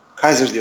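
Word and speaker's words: Kizer: Hücum güçleri zayıf ama çaylak QB Dishon Kaiser Kaiser diye okunuyor değil mi? – Evet evet.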